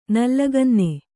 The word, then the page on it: ನಲ್ಲಗನ್ನೆ: Kannada